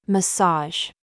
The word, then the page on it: genre: American English